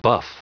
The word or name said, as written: buff